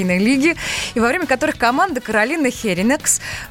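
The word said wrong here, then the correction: Hurricanes: э, правильно сказала? – Херикенс. – Херикенс. Херикенс.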